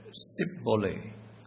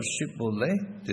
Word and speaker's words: Shibboleth: The Ephraimite escapee in Judges chapter 12, verse 6b said, "Sibboleth," for he could not frame to pronounce it right. The Ephraimites could not pronounce the SH sound. Instead of Shibboleth, they said Sibboleth.